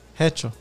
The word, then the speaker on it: hecho: How you say it? Hencho de Mexico. – Hecho. – Hecho. Hecho de Mexico, bro.